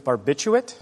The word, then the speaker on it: barbiturate: In this word for sedative drugs like phenobarbital, you can say the second R or just fly past it. – What is barbiturate? – Yes.